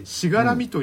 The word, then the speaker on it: 柵: 漢字の柵と書いて、そうなんです、うん、で調べてみたらですね、漢字の柵の別の読み方としてしがらみという読み方が、うん、